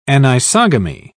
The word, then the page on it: anisogamy: British English